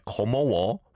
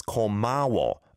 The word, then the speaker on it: 고마워: Sounded at the end like 고마워, but it's 고마워. An O vowel sound to an A vowel sound.